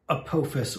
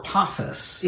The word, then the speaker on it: Apophis: That means Apophis or Apophis. – Yeah. – Or Apophis, however you want to pronounce it.